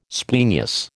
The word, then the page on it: splenius: American English